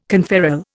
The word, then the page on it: conferral: Indian